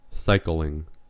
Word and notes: cycling: American English

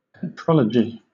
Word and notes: petrology: British English

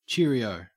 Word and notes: cheerio: Australian English

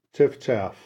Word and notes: chiffchaff: American English

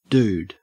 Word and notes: dude: Australian English

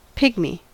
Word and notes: pygmy: American English

